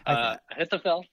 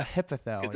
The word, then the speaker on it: Ahithophel: or I think it was Ahithophel, or however you say his name. – Right. Ahithophel. Yeah. – Ahithophel.